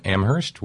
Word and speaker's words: Amherst: and thank you to Terry in Amherst, where only the H is silent.